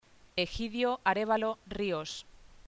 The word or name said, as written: Egidio Arévalo